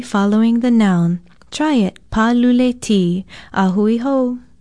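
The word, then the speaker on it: pālule-T: Aloha, I'm Leilani Poliahu of Hawaii Public Radio, and our Hawaiian word of the day is paluleti. It is spelled P-A-L-U-L-E hyphen T with a kahako over the A.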